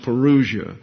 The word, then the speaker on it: parousia: But according to the New Oxford American Dictionary, it's pronounced parousia.